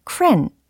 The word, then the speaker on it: crayon: 네, 그리고 참고로 crayon 이거 있잖아요. 그것을 호주에서는 cran 이라고 한대요.